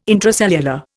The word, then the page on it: intracellular: Indian